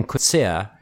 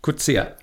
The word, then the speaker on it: Coetzee: So that's how you say his name. – I was just thinking the same thing. – He Coetzee. – I've always said Coetzee. – Yeah, you say Coetzee. Yeah. Americans usually say Coetzee, but it's Coetzee.